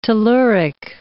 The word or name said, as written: telluric